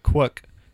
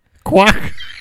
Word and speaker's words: Quook: His first name is Quook. It rhymes with cook. – Does it have a book? – Oh. It rhymes with book. – Quookbook? – Quook.